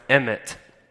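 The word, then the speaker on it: emet (אמת): But the word truth is actually biblical truth comes from a word that's pronounced emet.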